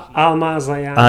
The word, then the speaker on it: Almaz Ayana: Um, Ama- Ayana, I can't say her name. Anyway, she. Uh, Almaz Ayana.